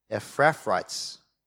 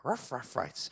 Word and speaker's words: Ephrathites: They were, all right, KJ told me how to pronounce this, Ephrathites. Sounds like a dog. Ephrathites.